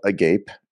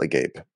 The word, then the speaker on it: agape: The venue is called, like, I don't think it's pronounced this way, but it is definitely spelled Agape, A-G-A-P-E. I think it's probably pronounced like Agape. – It's Agape.